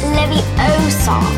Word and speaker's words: Leviosa: You're saying it wrong. – It's Leviosa.